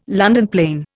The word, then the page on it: London plane: Indian